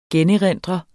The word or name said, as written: generindre